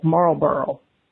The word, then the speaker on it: Marlboro: While I don't have a very pronounced Southern accent, the way we say Marlboro is as if both parts of the word have the letter L. So Marlboro.